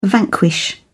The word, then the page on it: vanquish: British English